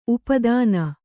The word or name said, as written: ಉಪಾದಾನ